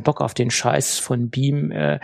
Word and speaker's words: BME: Beam wird es ausgesprochen. Habe ich mich erstmal erkundigt, ob es wirklich Beam ausgesprochen wird. Das ist korrekt, obwohl es nicht so geschrieben wird.